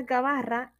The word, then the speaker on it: gabarra: Esta palabra gabarra es una palabra llana, no se acentúa en la penúltima sílaba debido a que esta termina en vocal.